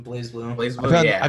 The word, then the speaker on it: BlazBlue: BlazBlue.